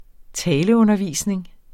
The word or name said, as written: taleundervisning